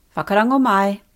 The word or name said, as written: whakarongo mai